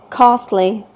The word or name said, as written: costly